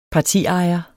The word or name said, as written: partiejer